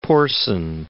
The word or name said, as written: Porson